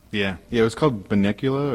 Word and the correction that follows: Botanicula: or Binocula or something like that was the last release. Yeah. – I don't know if I trust you pronouncing it. – Yeah, and we've given him a hard time on many episodes. It's actually Botanicula.